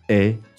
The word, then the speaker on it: hais: Oui, ça c'est l'infinitif, mais on prononce je hais, tu hais, right. – il hait.